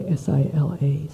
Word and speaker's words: sila: So, in some of the commentaries, the word sila, which is spelled, by the way, S I L A, sila, is explained by another word called uh, samadhana, which is harmony or coordination.